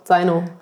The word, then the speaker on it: Zaino: Also wir haben, eigentlich kommt es von dem Wort, italienischen Wort Zaino, also mit dem Z-A-I-N-O, ohne das D. Und das wird aber im Italienischen halt Zaino ausgesprochen, aber die Deutschen würden halt immer eigentlich eher dann Zaino sagen, also ein hartes Z.